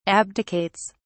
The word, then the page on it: abdicates: British English